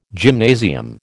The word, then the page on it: gymnasium: American English